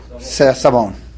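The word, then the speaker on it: savon: Right, how do you say it in French? Sabon.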